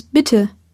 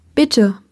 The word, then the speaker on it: bitte: In German, please is bitte, which you may recall from last lesson. Bitte. Let's break it down by syllable.